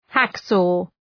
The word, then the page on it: hacksaw: ‘hæk,sɔ: